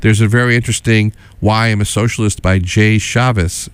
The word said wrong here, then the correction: Jai: I know Jai. I said Jay. I'm sorry. I meant Jai. Jai Chavez. Jai Chavez is the correct pronunciation, I believe.